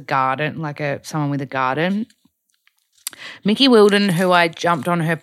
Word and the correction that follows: Willardon: pod a while ago, I hope I'm saying that right, Willardon, sorry, Mickey Willardon, she told me to try no cow protein bar.